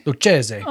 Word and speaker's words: Lucchese: I think it's the Lucchese? – Is it the Lucchese? – Lucchese. – Oh my God, I've been saying it wrong all these years.